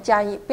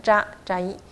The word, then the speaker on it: 扎伊: 阿帕拉贾伊坦，因为这个扎伊有点转音，所以就听的时候常常会滑掉啊，变成加伊，不要念成加，要念扎扎伊。